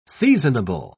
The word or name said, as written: seasonable